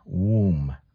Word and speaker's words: womb: This is a problem that many of us who are speakers of Portuguese and Spanish, we don't close the M as much. So when it's alone, because but we have to, okay? So it's M. Womb.